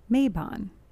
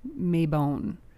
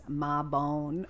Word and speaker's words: Mabon: The word is Mabon. That's how I say it. – That's the correct way to pronounce it. – Some people in Europe, I guess, say Maybon.